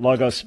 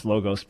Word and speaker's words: Logos: who is scholar in residence at Logos Bible Software. By habit, I pronounce Logos, but correctly, Logos Bible Software.